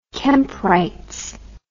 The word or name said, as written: Kemp Wrights